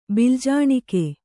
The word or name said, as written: ಬಿಲ್ಜಾಣಿಕೆ